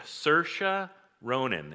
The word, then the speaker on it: Saoirse Ronan: Saoirse Ronan is how you pronounce the name of the woman who's the actress.